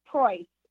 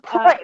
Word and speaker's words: Proy: Proy. So think of Proy. Like Roy Proy.